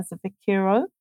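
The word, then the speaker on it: svikiro: Now, Mbuya Nehanda, full name Nehanda Chawe Niasa Sikana, she was a what's known as a Saviku Kiro. Sorry, a Savikiro. My pronunciation, I hope it's correct, or a spirit medium of the Shona people.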